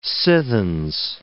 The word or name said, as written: sithens